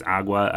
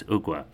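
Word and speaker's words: Ogua: We thought maybe it was Agua. Yeah, it's Ogwa.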